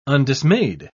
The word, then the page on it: undismayed: British English